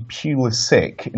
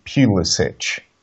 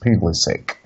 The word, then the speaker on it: Pulisic: You know, but oh yeah, by the way, I have it on good authority, it is actually Pulisic instead of like Pulisic. Because apparently it's the American thing and it is pronounced Pulisic.